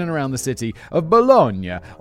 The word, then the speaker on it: Bologna: Is it Bologna? Bologna? I feel like I absolutely should know that. Come on, pronunciation man. Tell me what you got. Bologna. It's not Bologna for sure. – Bologna. – Bologna. Okay. – See, correct though.